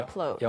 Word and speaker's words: upload: 네, 그런데 upload. 영어에서는 up, P 발음을 살려줘야 됩니다.